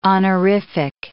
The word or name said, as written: honorific